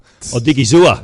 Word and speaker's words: Odighizuwa: Oh, Odighizuwa.